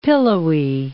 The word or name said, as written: pillowy